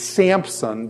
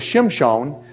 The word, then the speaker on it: Shimshon: But everybody usually pronounces it Samson based on the Septuagint, the Greek text of the Old Testament. They don't even know why. But actually, his name is Shimshon. Shimshon is actually his real name.